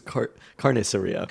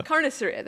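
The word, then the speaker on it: carnicería: Did I say that all wrong? Did I butcher that? – Yes. – Can you say it? – Well, I think I believe it's Carniceria. – Carniceria. There we go.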